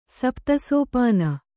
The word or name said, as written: ಸಪ್ತಸೋಪಾನ